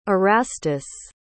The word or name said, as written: erastus